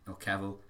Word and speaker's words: Cavill: They released a bunch of, like, pictures of Henry Cavill and Cavill, if you want to say his name correctly, and the other actors and stuff in, like, their get-ups.